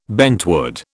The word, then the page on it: bentwood: British English